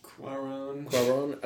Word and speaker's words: Cuarón: Cuarón.